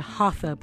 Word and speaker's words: hatha: I know for years I've been saying that word hatha, pronouncing the T and the H, but that's not so. Hatha yoga is the proper pronunciation of that word, and that is the yoga of movement or yoga of activity or to create heat.